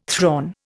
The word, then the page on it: thrawn: Indian